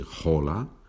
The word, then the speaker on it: hola: You know that the H is not pronounced in Spanish, so we don't say hola, we say hola, but we write the H.